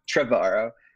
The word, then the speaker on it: Trevorrow: Trevorrow, I believe. – Trevorrow, written by Colin Trevorrow, and is creating it into a 7-part webcomic series called Duel of the Fates, which was the name Colin Trevorrow gave episode 9.